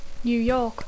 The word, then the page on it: New York: English